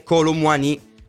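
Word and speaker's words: Kolo Muani: Leggo un po' di messaggi nel mentre da Twitch, ma la pronuncia è Colomwani o Colomwani, la pronuncia giusta è Colomwani. Colomwani, la cadenza francese, quindi Colomwani, accento sulla finale I.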